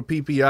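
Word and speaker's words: Phi Phi: We didn't vlog when we was in Phuket, but definitely go to PP Islands. – Yeah, it's a great place. – If you look at my Thailand reels, you'll see a lot of PP.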